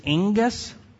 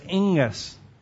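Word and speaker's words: angus (ἐγγύς): Now, this term near, it's angus. It's kind of how you say it. There's I always have a trouble with this one because there's two gammas next to each other. But it's angus, and it's an adverb, and it means near.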